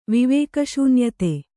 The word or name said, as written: ವಿವೇಕಶೂನ್ಯತೆ